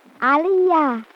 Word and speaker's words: Aliyah: Can you spell it, little girl? – Aliyah. A-L-I-Y-A-H.